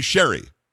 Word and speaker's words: Sherry: I didn't call you Cherry because I felt like it's spelled with a C. So it's actually Sherry.